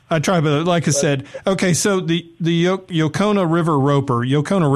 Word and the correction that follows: Yocona: River, now that must be a place y'all like to go hang out and vacation at. – Well, so, yeah, it's actually – pronounce Yachtna. Oh, of course. Yachtna, right? Of course it is, because that's me. I can mispronounce my own name.